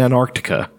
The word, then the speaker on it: Antarctica: Michael Forbes was having trouble, so this guy who was in Antarctica doing a fucking... – You're one of the only people that I've ever heard pronounce the extra C in Antarctica.